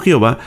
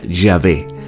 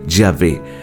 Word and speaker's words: Yahvé: Eh, la pronunciación, la Reina Valera 60 y la Biblia en español la trajeron como Jehová, pero su pronunciación más exacta al original sería Yahvé. Yahvé.